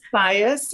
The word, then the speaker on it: Baez: Did I say that right? Baez? – Baez, yeah.